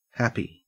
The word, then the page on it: happy: Australian English